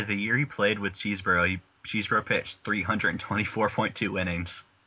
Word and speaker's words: Chesbro: Like, it looks like it should be pronounced Chesbro, but I think I read that it's actually pronounced Cheesebro.